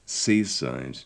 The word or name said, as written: seaside